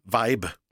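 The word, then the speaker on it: Væb: Nej, men det är alltså en V, danskt Ä och B. Och det blir då ett vibe, alltså på engelska.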